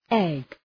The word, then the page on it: egg: eg